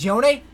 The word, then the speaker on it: Yone: And then Freak says his name is Yone. That's how it's pronounced.